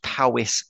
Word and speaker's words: Powys: And he sent me a message to say, you pronounced Powys perfectly Boyo.